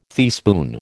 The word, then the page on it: teaspoon: American English